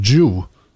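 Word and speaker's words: Zhou: Eh, och det är fortfarande den här Jew som det tydligen mm. uttalas på mandarin, Guangzhou Mm -hmm.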